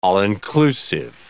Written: all-inclusive

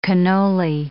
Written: cannoli

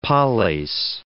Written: poleis

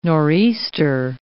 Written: nor'easter

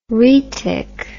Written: rhetic

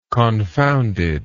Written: confoundedness

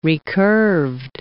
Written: recurved

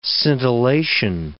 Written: scintillation

